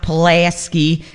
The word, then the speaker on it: Pulaski: Vito and Nick's, 8433 South Pulaski. Got to say it like a Chicagoan.